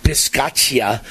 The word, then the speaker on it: Bisaccia: First of all, the Raiders coach, I can never pronounce his last name, but I, my understanding is it's pronounced Rich Bisaccia.